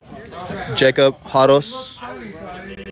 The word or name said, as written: Jacob Haros